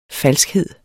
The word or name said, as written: falskhed